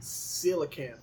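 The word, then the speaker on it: coelacanth: I feel like it's pronounced with an S. Look at the pronunciation. See the lines. – Okay, hold on. Hold the phone. – But I know it's a C. Colacanth. It's definitely how you spell it. Let's go to Wikipedia. – Let's see. – Silicanth. See, I was saying some stuff. Yeah, silicanth.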